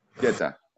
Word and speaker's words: Jeta: Uh, our special guest for today will be Jera. – Jera. – Jera, uh, am I pronouncing it correctly or should we No, it is Jeta. – Jeta, okay.